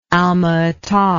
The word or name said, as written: almaata